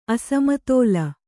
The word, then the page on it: ಅಸಮತೋಲ: asamatōla